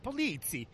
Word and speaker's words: Polizzi: Because I'm like, Polizzi? Did I say that right? He's like, yeah, close up. Polizzi.